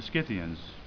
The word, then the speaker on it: Scythians: Then there were the Scythians. That's how you pronounce it.